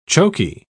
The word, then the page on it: chokey: British English